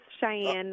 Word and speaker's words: Cheyenne: And joining us on the phone, is it Cheyenne? I want to make sure I'm pronouncing this correctly. – Yes. Cheyenne Knight.